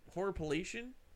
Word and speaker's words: horripilation: See, is that how you say that word? Horripilation? – Yeah, that's horripilation.